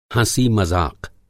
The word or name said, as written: हँसी मज़ाक़